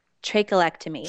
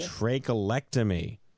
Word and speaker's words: trachelectomy: What is the correct pronunciation? – Trachelectomy. – Trachelectomy.